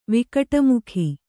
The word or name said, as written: ವಿಕಟಮುಖಿ